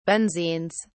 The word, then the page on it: benzenes: British English